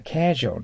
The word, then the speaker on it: casual: casual と発音します。日本語でカジュアルですけど、カじゃないです。英語の場合は、 A の字、 A が強く発音する第一アクセントの場合には、 A となります。 casual となります。